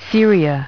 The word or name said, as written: Syria